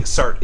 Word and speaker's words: Sartre: I've heard Sartre pronounced before, and I think Sartre is a correct pronunciation.